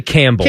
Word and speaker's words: Campbell: I'm saying Campbell because I know there's a P Campbell. – In there. – Just say Campbell. – Campbell, like the soup.